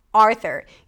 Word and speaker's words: Arthur: There is no U sound, even though it's spelled with a U, there is no U, Arthur.